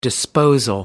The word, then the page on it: disposal: American English